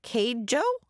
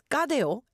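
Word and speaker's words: Cadejo: I'd be like, that's not Keijo. I'd be like, what? But it's pronounced Kadeo.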